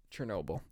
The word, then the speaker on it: Chernobyl: Chernobyl, that's what it is.